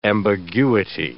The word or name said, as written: ambiguity